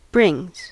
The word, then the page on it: brings: American English